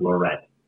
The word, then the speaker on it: Larrey: It's pronounced Lorette. – Lorette. – Lorette. – Lorette. – It's actually L-A-R-R-E-Y.